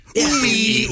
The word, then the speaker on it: Ooi: Uwe. It's spelled O-O-I. And it says, according to the pronouncer here, it's long U and then double E.